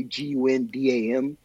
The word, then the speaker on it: Gundam: One of the American Olympic announcers didn't know how to pronounce Gundam. I don't even know how you could not. It's literally Gundam, but they pronounce it like some foreign name.